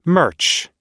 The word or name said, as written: merch